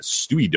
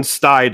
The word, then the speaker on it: Stuy: I think it is S-T-U-Y, but I think it's pronounced Stye. – Like, there's a place in New York called Stuyvesant, which is spelled S-T-U-Y, you know, and so on. So I think it's Brooklyn Stye Dome.